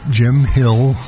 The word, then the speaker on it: Jim Hill: Yeah, Jim Hill, J-I-M, last name H-I-L-L.